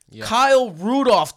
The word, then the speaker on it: Rudolph: But he even finished behind Trey Burton, Kyle Rudolph, don't pronounce the L, Blah.